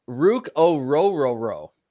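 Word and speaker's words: Ruke Orhorhoro: Rook is how you pronounce it, apparently. – Is that it? I need to learn that name because they might pick that one in the second. What was it again? – Rook O'Roro. Truly, that's how you pronounce his name. He said it in an interview.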